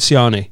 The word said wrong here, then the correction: Shani: It's Shani. – Shani.